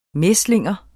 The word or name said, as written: mæslinger